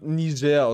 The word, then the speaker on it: Niger: Es wird Niger ausgesprochen. Es ist französisch.